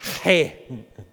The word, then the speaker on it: He: Yes, but it's hey. Right? And you notice this when you hear Hebrew speakers pronounce words. It's hey, you know, that's how it works. It's a rough kind of H sound that is followed by an E sound.